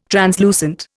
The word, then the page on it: translucent: Indian